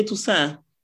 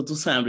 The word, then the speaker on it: Tusse: Nej, men jag säger Tusse. Eh, okay. – Eh, men, eh, det är ju hur man uttalar mitt namn. Liksom. Ja. Eh, så, för Tusse är lite försvenskat så det blir svårt för, för dem att, att ta sig an den. Så Toussaint brukar funka.